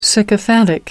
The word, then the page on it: sycophantic: American English